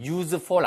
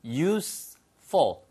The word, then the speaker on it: useful: 이게 절대 발음이 useful 아니에요. use고요. useful. useful.